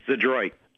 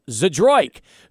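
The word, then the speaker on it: Zdroik: How do you pronounce it? – Zadroit. – Oh, it's just like it's spelled, Zadroik, who yep.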